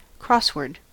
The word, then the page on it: crossword: American English